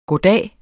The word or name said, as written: goddag